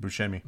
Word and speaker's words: Buscemi: The film stars Adam Sandler, Kevin James, Jessica Biel, Steve Buscemi, Rob... Is that how you pronounce that? – Buscemi.